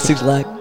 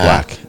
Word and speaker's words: 6lack: I think it's pronounced Black though, isn't it? – It's pronounced Black. It's Six Lack pronounced Black.